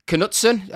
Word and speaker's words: Knutsen: And then, of course, there's the Norwegian manager of Glimt, Knutsen. Are we pronouncing it? – Jetil Knutsen. – Yeah. – I think the first K is silent and the second K is not.